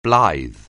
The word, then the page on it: blithe: anglais